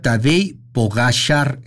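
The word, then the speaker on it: Tadej Pogačar: Dice, se pronuncia Tadej Pogacar en vez de Tadej Pogacar.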